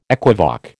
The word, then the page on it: equivoque: American English